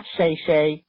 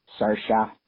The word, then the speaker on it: Saoirse: It's Saoirse, not Shayshe, not Saoirse, not Sour Reese Witherspoon. Saoirse.